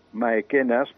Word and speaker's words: Maecenas: Maecenas, pronunciat així a la romànica, ja mecenes.